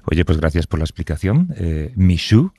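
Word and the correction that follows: Mixue: la cadena, ¿lo he dicho bien? ¿Mishu se llama la cadena? ¿Se dice? – Mishue. – Mishue. Lo he hecho totalmente mal.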